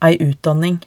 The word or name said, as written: ei utdanning